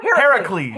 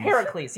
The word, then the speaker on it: Heracles: Heracles, yes. It is Hercules. Heracles. I don't know why I can't speak Heracles. I didn't do my topic on Heracles, yeah. All right? – Because it's spelled like Hera, C-L-E-S.